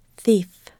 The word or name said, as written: thief